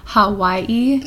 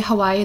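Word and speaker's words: Hawaii (Hawaiʻi): Which is odd that their literal name is Hawaii. – Yeah, with a W. – Yeah, so instead of when they pronounce it, they don't say Hawaii, they say Hawaii. Hawaii. – With a V? – Yeah.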